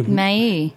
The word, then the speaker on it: Maille: I never know how to pronounce this. M-A-I-L-L-E. – May.